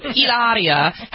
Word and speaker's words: Hilaria: And she corrects people and says that they don't say her name right. It's Hilaria. So Hilaria has welcomed a 6th baby, and she posted this cryptic photo with this newborn, and no one, they're not commenting.